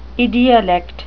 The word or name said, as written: idiolect